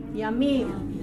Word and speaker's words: yamim: Okay, that's the O sound there, the O syllable, O, O, yom. But in plural it becomes yamim.